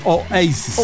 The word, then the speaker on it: Oasis: En inglés, lo Oasis. Pero acá le decimos Oasis porque Oasis. Se escribe Oasis y se pronuncia Oasis.